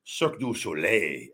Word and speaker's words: Cirque du Soleil: Mason Ryan actually, if I'm not mistaken, lives here in Vegas and works for Cirque du Soleil. I don't know why I said it like that. I always had trouble pronouncing that Cirque du Soleil. Cirque du Soleil, where I believe he's one of the bases and like holds people and does really cool feats of strength.